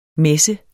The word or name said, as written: messe